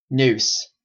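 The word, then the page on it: noose: Canadian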